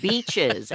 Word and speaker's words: Beaches: And again, we got to be careful how we pronounce that. Beaches, as in like Florida beaches.